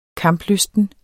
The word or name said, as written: kamplysten